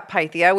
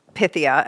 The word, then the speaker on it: Pythia: Yeah, Pythia, which is the Greek pronunciation is Pythia, as in the Pythia, which was the oracle at Delphi.